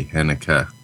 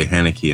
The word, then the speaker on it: Haneke: Also, can all Americans please stop pronouncing Germanic names as if the E on the end sounds like an E? Haneke is actually Haneke. Nietzsche is Nietzsche. I hear you say Haneke and I reach for my hanky.